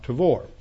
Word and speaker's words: Tabor: So if you hear about the Tabor, that's how it's pronounced. It's Mount Tabor.